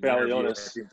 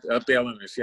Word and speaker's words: Balionis: Balionis. – Balionis, yeah.